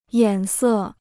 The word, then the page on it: 眼色: yǎn sè